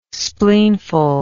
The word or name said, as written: spleenful